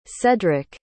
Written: sedrick

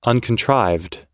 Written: uncontrived

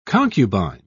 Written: concubine

